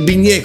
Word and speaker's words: bignè: poi il bignè con l'accento sulla E è tutta un'altra cosa.